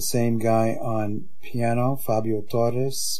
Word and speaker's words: Torres: The piano solo was Fabio Torres, or pronounced in Portuguese, Torres, and Paolo Paulelli was playing six-string bass, and he did a solo, too.